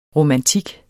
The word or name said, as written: romantik